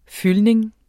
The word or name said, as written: fyldning